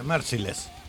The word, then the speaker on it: Merciless: ¿Es Merikles o cómo carajo se dice? – Si se escribe, no, ahí dice otra cosa, dice Merciless. – Merciless, me leí mal.